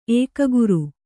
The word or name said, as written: ಏಕಾಗ್ರ